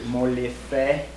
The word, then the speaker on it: Molefe: Molefe. That's the first one. – Molefe. – Molefe. – Molefe. – Just M-O-L-E-F-E.